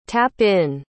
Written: tap-in

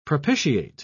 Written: propitiate

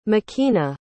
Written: makena

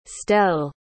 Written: stelle

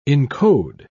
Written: encode

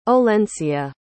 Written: Olencia

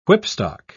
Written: whipstock

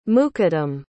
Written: muqaddim

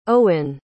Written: owin

